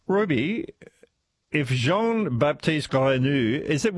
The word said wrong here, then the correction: Grenouille: was that a good pronunciation? – Is it G-R-E-N-O-U-I-L-L-E? I think if it's the French word, it's Grenouille. – Grenouille, thank you.